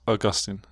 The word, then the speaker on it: Augustine: I don't know how to pronounce that. Augustine. – Augustine, yeah.